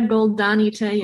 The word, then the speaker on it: Goldani Telles: so your your actor Julia uh, Goldini, is that how you say it? Tell us. Julia Goldani Telles.